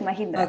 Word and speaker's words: Mahindra: ¿cómo es Mahindra? Sí, así es, Mahindra.